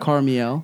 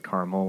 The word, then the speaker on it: Carmiel: It's called Carmiel. – Yeah, I was gonna say it like Carmel, like a real Southern person.